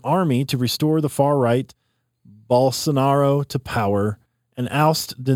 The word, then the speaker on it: Bolsonaro: Bolsonaro? – That was pretty good, yeah.